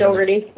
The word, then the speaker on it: Dougherty: Did I pronounce that right? I always called him JD. I never really... – Dougherty. No, Dougherty's right.